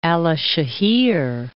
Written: Alasehir

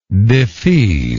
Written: defease

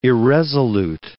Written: irresolute

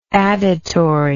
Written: additory

